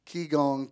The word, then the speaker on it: Qigong: Qigong postures, thank you.